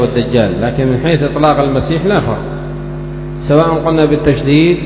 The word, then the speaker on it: المسيح: المسيح الدجال والمسيح عيسى كلاهما يقال له المسيح، لكن أبو داود هنا يقول المسيح الدجال يثقل والمسيح عيسى يخفف. كيف ينطق وليد؟ بتشديد ماذا؟ السين. يقال المسيح، المسيح الدجال على هذا القول، والمسيح عيسى.